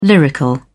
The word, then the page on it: lyrical: British English